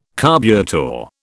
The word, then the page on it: carburettor: British English